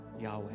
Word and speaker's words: Yahweh: when you pronounce God's name, it's Yahweh.